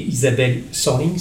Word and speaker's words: Isabelle Sorling: Le quartet, vous à la trompette, Benjamin Mousset au piano et à Fender Rhodes, Fabrice Moreau à la batterie et Isabelle Sorling, c'est comme ça qu'on prononce ? – Absolument.